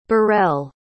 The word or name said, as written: borel